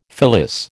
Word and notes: fillis: American English

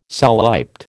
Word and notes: soliped: American English

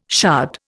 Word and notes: shard: Indian

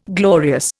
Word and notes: glorious: Indian

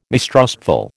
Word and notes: mistrustful: American English

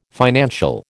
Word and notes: financial: American English

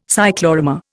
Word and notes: cyclorama: Indian

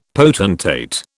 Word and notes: potentate: British English

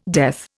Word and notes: death: Indian

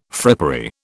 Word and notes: frippery: British English

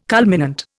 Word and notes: culminant: Indian

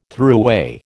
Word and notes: thruway: American English

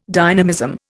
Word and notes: dynamism: Indian

